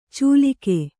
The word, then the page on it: ಚೂಲಿಕೆ: cūlik3